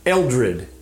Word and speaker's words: Eldred: I am today sitting in my office with Jim Eldred.